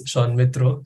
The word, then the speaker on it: Sanmitra: I'll just start by correcting my name. It's Sanmitra.